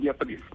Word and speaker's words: Beatriz: Pues en español es Beatriz. – Ah, bueno. Y el nombre en holandés es Beatrix. Ah. pero, pero obviamente en español pronunciamos Beatriz el nombre.